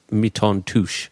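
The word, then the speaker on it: Mi-Teintes Touch: It's spelt touch, but it's pronounced Miton Touche.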